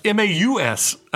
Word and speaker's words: Maus: Moss. About the Holocaust. – Oh yeah. MAUS. – Yes. – Yeah, and I think you're saying it right. I was just thinking moss, like on a rock.